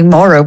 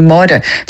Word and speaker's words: Maura: And in fairness, because I'm from the Gaeltacht, my name is, and most people who say it, they do anglicise it, they say Maura, whereas really the right way to pronounce it would be Maura. Like, forget it. That's not going to happen. They're not going to get that R roll straight away.